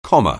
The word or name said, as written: comma